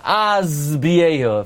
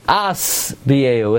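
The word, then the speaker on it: aspiehu (אשביעהו): Now, by the way, most people mispronounce that pasuk. Most people say, Oirech yamim aspiehu. If you listen to it, they say z. They turn into an insect, and they start zuzzing. It's aspiehu, with a sin, not a zayin.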